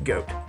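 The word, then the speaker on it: Goht: Which, please, for the record, I did look this up, and it is actually pronounced goat.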